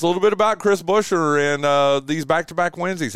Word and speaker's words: Buescher: is it Buescher? How do you say his name? – Buescher. – Yeah. Okay, very good.